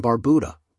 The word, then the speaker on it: Barbuda: Antigua, pronounced Antigua, and Barbuda, Barbuda, is located in the heart of the Caribbean Sea.